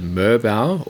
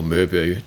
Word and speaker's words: Merbau: Apparently, the the the Merbau or, or Merbu, depending where you are in the country, how you pronounce it, apparently it's not incredibly responsibly sourced.